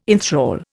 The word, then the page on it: inthrall: Indian